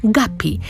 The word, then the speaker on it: Guppy: Pisze się Guppy, ale wymawia się po angielsku Guppy.